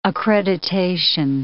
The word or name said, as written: accreditation